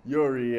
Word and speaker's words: Jiří: Jiří Procházka. Mad fight. – Say it right. – You get me. Jiří. Yeah.